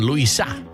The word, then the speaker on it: Luisa: Der neue Hit von Luisa. Endsilbenbetonung, wenn ihr sie mal ansprechen solltet beim Frühstück.